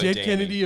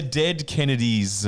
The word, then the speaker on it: Dead Kennedys: Is so Dead Kennedy a big band? – They're called the... Just listen. You're saying it wrong. The Dead Kennedys.